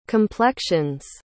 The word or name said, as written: complexions